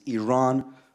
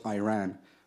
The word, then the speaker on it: Iran: You say you support the Iranian people, but you're pronouncing our country's name wrong. Our country is named Iran, not Iran. Please, once and for all, let's get this clear.